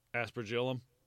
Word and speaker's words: aspergillum: Aspergillum.